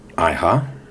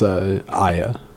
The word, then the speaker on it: Iha: James Iha. – I believe it's Aya. It's just pronounced Aya. – I believe it's Iha.